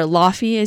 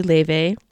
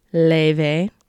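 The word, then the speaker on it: Laufey: And of course, with like Leve or Lofi, as you probably know her as, the way to pronounce her name is actually Leve. Say it with me now, Leve.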